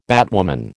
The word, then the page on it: batwoman: American English